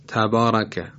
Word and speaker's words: تبارک: مثلا کلمه تبارکه، تبارکه، ر در اینجا مفتوحه و باید تفخیم بشه، یعنی درشت تلفظ بشه، اینطور تبارکه.